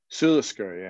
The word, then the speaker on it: Sula Sgeir: And how do you pronounce this? Sula Sgeir? – Sulasgir, yeah. – Sula Sgeir.